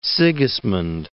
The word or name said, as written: Sigismund